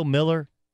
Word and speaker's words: Mueller: Remember Bill Miller? Which is spelled Mueller.